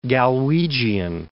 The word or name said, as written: Galwegian